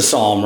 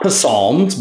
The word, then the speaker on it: psalm: I mean, it's a psalm, right? Not psalms, but psalm. The P is kind of silent. So I don't know if you've all talked about that yet, the silent P.